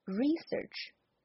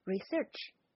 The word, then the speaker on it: research: 它的名词形式，刚才我读的那个要读 research，那么动词形式呢则要读 research。